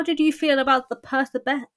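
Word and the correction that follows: Percabeth: I can't say that word. How do you say it? Persebeth. There we go.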